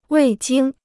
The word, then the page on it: 未经: wèi jīng